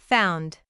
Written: found

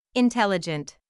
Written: intelligent

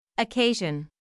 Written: occasion

